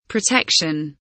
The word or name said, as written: protection